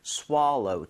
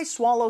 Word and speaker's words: swallow: swallow보다는 swallow. 조금 입모양을 벌려서 발음해 주시면 좋겠습니다. So what if I swallow some water?